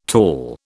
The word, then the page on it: tall: British English